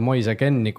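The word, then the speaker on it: Moise Kean: Moise Ken, niimoodi hääldatakse seda meest siis väidetavalt.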